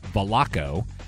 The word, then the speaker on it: Velasco: Although, because he's Argentinian, he pronounces it Velaco.